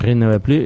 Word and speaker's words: rien ne va plus: I ecològic, perquè ja no va més, és a dir, rien ne va plus, és a dir, això que diuen els croupiers, els casinos.